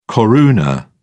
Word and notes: koruna: British English

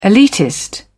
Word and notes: elitist: British English

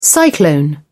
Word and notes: cyclone: British English

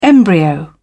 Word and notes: embryo: British English

